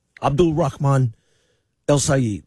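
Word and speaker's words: Abdul Rahman El-Sayed: He said if you can't say his name right, to keep it his name out of your mouth. So yeah, I said it right. Abdul Rahman El Sayed.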